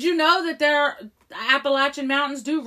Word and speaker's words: Appalachian: Yes. Well, you could say Appalachian, but the Appalachian people say Appalachian. – Okay.